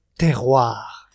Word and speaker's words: terroir: I'm going to have to teach you first how to pronounce it. – Okay. – The word is terroir.